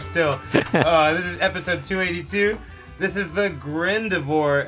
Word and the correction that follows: Gardevoir: episode. – Gardevoir. – Is that how you say it?